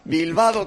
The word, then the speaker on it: Bilbao: Bilbao que rima con bacalado. – Claro. – Y con helado.